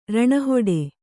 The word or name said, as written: ರಣಹೊಡೆ